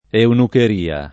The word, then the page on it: eunucheria: italiana